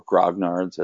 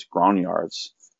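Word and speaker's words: grognard: That's, the power of the X card, I think, so I deal with a lot of individuals that would be termed grognards, or grognards as it's usually pronounced. But I've been schooled on it, and the correct pronunciation is grognards, because it's a French word.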